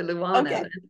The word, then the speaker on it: Luana: And you did good with the Luana.